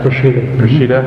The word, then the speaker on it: Peshitta: Peshitta, mm-hmm.